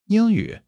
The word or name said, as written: Ying1 yu3